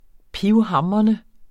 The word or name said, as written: pivhamrende